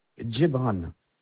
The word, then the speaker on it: Djebrane: Alors, votre nom, c'est Djebran.